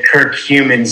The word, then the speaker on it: curcumin: Say it for me. curcumin, C U R C U M I N.